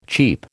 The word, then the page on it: cheep: American English